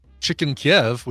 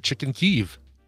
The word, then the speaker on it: Chicken Kyiv: By the way, people are pointing out that now Chicken Kiev, we have to pronounce it Chicken Kyiv.